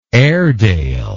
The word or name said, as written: airedale